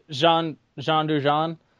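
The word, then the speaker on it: Jean Dujardin: Oh, Jean Dujon.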